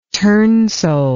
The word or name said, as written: turnsole